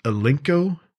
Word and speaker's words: Alinco: And then the last one, I believe it's pronounced Alinco, A-L-I-N-C-O, Alinco DJ VX50T.